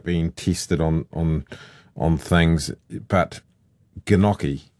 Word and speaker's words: gnocchi: I would say gnocchi.